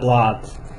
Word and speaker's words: blood: Blood. А там не О, там А, Blood.